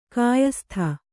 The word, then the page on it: ಕ್ಯಾಸತ್ತೆ: kyāsatte